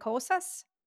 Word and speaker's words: Kosas: Is it Kosas? – Yeah. – K-O-S-A-S.